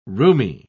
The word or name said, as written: rheumy